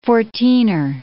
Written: fourteener

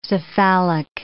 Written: cephalic

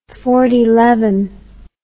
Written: fortyleven

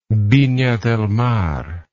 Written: vina del mar

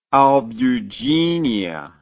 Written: albugineous

